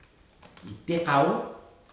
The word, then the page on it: tekau: futuna aniwa